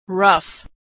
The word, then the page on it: rough: gh